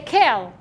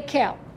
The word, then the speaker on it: Kel: Number three is the word Aleph Lamed, which we don't pronounce correctly because it's a divine name. So when we are not praying, we say Kel. Instead of E, we say Ke. Say Kel.